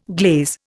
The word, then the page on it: glaze: Indian